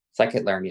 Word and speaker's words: scikit-learn: So you wrote this blog post about Scikit Learn. Am I saying that right? – Scikit Learn, yes.